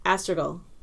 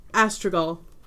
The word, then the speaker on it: Astragal: Astragal. – Astragal. – Like go, almost go like seagull, but not... – Astragal. – Astragal. – A-S-T-R-A-G-A-L.